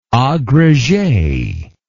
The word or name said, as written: agrege